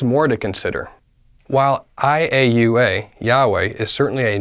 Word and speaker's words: Yahweh: I-A-U-A, iota, alpha, upsilon, and alpha, pronounced as Yahweh.